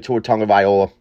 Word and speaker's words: Tua Tagovailoa: And listen, we all feel bad for what happened to Tua Tagovailoa, obviously. That's how you say it over there at 1420 Sports.